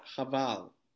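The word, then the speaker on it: Haval: I think it's pronounced Havel, not Havel. – Havel. – I think it's pronounced Havel. Yeah.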